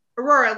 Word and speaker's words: Aurora: Aurora, like the Disney princess.